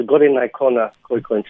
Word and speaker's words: Goringhaicona Khoena: So it's the Goringhaicona Khoena Traditional Council.